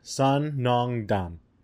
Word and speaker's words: Sun Nong Dan: And that place is called Sun Nong Dan. – Sun Nong Dan. – And then Dan, like Dan. Like yeah. – Dan from Street Fighter, the pink dude. – But it's pronounced Dan.